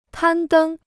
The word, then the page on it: 攀登: pān dēng